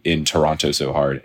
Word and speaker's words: Toronto: Ooh, Toronto. Mm-hmm. Or as I've been told to pronounce, Toronto. Yeah. I learned the hard way because I've always There's no second T.